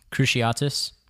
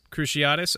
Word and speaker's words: Cruciatus: Cruciatus? How do you say this? – Cruciatus. – Cruciatus, okay. Cruciatus curse, which is basically just a torturing spell, curse.